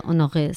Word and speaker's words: Honoré: No sé si está bien pronunciada como siempre, pero Honoré, sí, ¿no? – Sí.